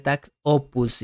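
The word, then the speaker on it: takt op.: Se escribe Tact Op Destiny pero pues se pronuncia tax opus. Y eso no lo digo yo, lo dice eh, el autor. – El autor. – Si en un comunicado salió que se pronunciaba tax opus, entonces vamos a respetar al autor.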